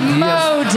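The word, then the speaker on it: Modist: Yeah, Modest. Yeah, Modest Brewery in Minneapolis. Yeah. No, it's spelled. Come on, it's M-O-D-I-S-T. – Modest. – He has the right to call it Modest if he needs to. – Yeah, it's spelled stupid.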